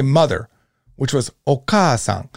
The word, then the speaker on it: okaasan: The second syllable, oka, is this first ka. And then we would add an a. Oka-a. We already got three syllables of this word, three letters of this word. We don't know sa, and we won't know un until the next few sheets. All right, so okaasan. All right, so if we look at kaki kukeko, you can go ahead and just practice these.